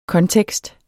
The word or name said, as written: kontekst